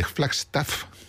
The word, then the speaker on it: Flagstaff: Bueno, pues el Coal Mine Canyon está al norte de Flagstaff, de Flagstaff, uh-huh, así se pronuncia,